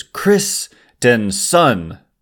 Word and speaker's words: Christenson: So Christensen, it's the way the Giants say it's pronounced is Christensen, which is I just don't know how to say that well, but my best effort is Ryan Christensen.